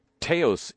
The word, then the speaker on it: Taos: Contracts with one of these companies, Taos Industries, rhymes with chaos,